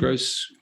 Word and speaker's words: Grose: Grose.